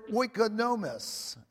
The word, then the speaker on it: oikonomos: The word stewards comes from the Greek word oikonomos. It's spelled O-I-K-O-N-O-M-O-S.